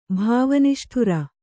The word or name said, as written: ಭಾವನಿಷ್ಠುರ